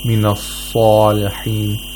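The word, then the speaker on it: مِنَ الصَّالِحِينَ: You should not try to, you know, make too much of a whistling sound by when pronouncing sad. For example, some people say minas-salihin, minas-salihin. This is incorrect because you're changing the place of articulation of sad.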